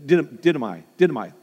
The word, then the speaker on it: didomai: The word give is the word dotome. If I say that right, didomai, yeah.